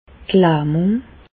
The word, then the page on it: d' Lähmùng: Bas Rhin; Reichshoffen